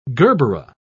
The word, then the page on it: gerbera: British English